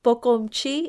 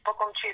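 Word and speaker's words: Poqomchi': And that's pronounced Pocomchi. It's the indigenous language, isn't it, of the Mayan heritage? – Yes, Pocomchi.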